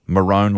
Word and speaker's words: maroon: First of all, the colour maroon is no longer pronounced maroon. It is maroon like bone. They are the maroons.